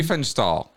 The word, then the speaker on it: Riefenstahl: Leni Riefenstahl. I will continue to butcher her name forever. – Remember, like reefer, like smoke a reefer. – Reefer, okay. Riefenstahl. There we go. Riefenstahl.